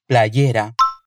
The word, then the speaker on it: playera: Playera. Playera é uma das formas diferentes de dizer camiseta em espanhol, mas preste atenção à pronúncia. A Lisette é mexicana, por isso ela pronuncia o Y como IE, playera.